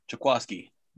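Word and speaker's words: Jaquiski: How do you pronounce his first name? – Jaquaski.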